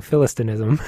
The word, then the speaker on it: Philistinism: So when I came to this word, Philistinianism. – Dear listeners, how many syllables can add to Philistinism? – Thank you, Philistinism.